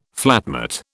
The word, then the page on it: flatmate: British English